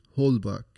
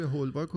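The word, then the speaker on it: Holbach: هولباک بله اینا میشناسی خیلی تلفظ بله. اسپل اسمشون عجیبه هولباک و D H O B A C H می نویسن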